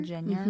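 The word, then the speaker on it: Jenyer: It's J-E-N-Y-E-R. – Jenner? – Jenner.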